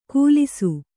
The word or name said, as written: ಕೂಲಿಸು